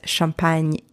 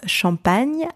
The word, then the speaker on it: champagne: Two syllables. Champagne. Repeat after me. Champagne. Champagne. So first syllable, the letters C-H-A-M are pronounced champ. Champ. So you've got the CH, the letter CH, pronounced sh, sh. And you've got the nasal sound en.